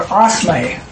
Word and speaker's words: osme: Oh, it's pronounced O-S-M-A-Y. – Osme. Okay. – They spell it, though, O-S-M-E.